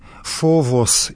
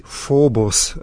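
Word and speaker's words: фовос (φόβος): В стихире «страх» по-гречески «фовос», «фобос» в более древнем произношении этого слова.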